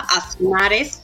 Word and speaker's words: Aznárez: Bueno, mi apellido, porque tiene dos Zetas. O sea, una Z de, detrás de la primera A, Aznares, y otra al final. – Y como soy andaluza, pues lo pronuncio como aquella, de aquella manera.